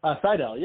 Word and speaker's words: Sidell: Sidell, yeah.